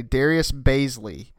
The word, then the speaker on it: Darius Bazley: Draft picks-wise, they signed, and tell me if I pronounce this correctly, Darius Bazley.